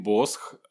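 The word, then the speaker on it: Bosch: Да, но они читают Бош, и у них по правилам так читается, потому что SH читается как Ш, да? Ну, не Ш, а такой звук Ш.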